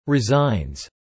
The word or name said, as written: resigns